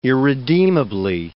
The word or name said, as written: irredeemably